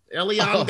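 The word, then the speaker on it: Elián: I know, that's how it's pronounced. You said it wrong, goddammit. You always talk about me saying things wrong. Elian Gonzalez, dude.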